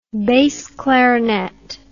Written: bass clarinet

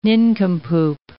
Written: nincompoop

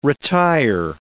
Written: retire

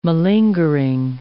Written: malingering

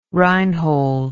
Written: Reinhold

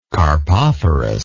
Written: carpophorus

